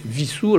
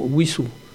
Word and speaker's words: Wissous: Donc aujourd'hui, tout le monde dit Vissou, alors qu'on devrait dire historiquement Wissou.